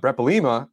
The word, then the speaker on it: Bielema: The Patriots are not the only ones who do this, but but this is something that happened with Brett Bielema, or is I'm saying his name right? – Bert. Yeah, you're saying it right.